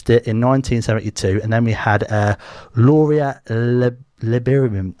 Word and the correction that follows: Lieberman: I can't even pronounce that. Do you know how to pronounce that? Hang on, I'm going to ask someone off air here. Do you know how to pronounce that? – Lieberman. – There you go. Laura Lieberman, yeah? There you go. I said it right that time.